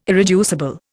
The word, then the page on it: irreducible: Indian